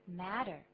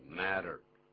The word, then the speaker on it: matter: 稍微注意一下发音，拼的时候是写两个 T，T 本来是念作 t，可是呢，在这个地方，美式英语的发音是 the matter 这么念的。 – 对，是 matter，而不是念 matter。那如果是 matter，老师有这样发音，那是英式英语啊。